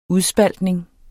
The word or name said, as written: udspaltning